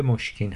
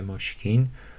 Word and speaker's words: مشک: خال مشکین گفتیم دیگه، مشک یا مشک که جفتش درست موسکا در انگار یونانی هم هست کلمهش، در عربی و فارسی هر دوشو تلفظ میکنیم، البته عربا مسک میگن و مسک.